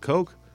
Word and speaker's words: Koch: K-O-C-H would be pronounced Coke.